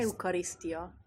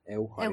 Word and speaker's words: Eukarisztia: Eukarisztia. – Mert ők hogy ejtik? Euchariszt.